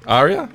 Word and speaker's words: ARIA: Is that how you pronounce it? ARIA? A-R-I-A? ARIA? Okay. – Yeah.